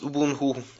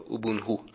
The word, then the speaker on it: Ubuntu: It's pronounced Ubuntu. The NT is pronounced like an NH in Kinyarwanda, so Ubuntu.